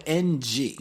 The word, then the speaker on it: Ng: Well, your last name is spelled NG. – Yes, that's the number five, right? Like, so we, okay. You know, the Cantonese words, right. Yai, some say ng. So some people say ng, but that's like hard to say.